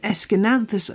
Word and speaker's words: Aeschynanthus: It's spelled A-E-S-C-H-Y-N-A-N-T-H-U-S. How on earth? Well, just break it down. It's S-K-N-A-N-T-H-U-S, with the emphasis on the nan.